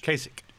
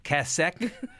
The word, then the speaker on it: Kasich: Kasich. – Kasich. – Rhymes with basic. – Say it again. – Kasich.